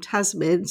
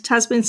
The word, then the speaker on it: Tazmin: But to be fair, I've been called Tamsin so many times instead of my real name, Tasmin.